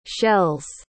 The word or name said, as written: shell's